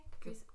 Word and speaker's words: caprese: We ended up getting caprese. Caprese? Caprese? I never say that right. – Caprese salad? – But it was tomatoes and mozzarella of some sort. – Yeah, that's the caprese. That's caprese. – But yeah, it was fun time.